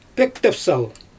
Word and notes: pöktepsal: Xyzyl

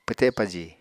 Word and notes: pwte paji: Apatani

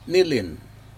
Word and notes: nilin: Aneityum

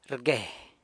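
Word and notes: rgue: San Lucas Quiaviní Zapotec

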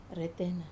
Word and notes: retena: wamesa

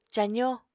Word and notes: cazó: apatani

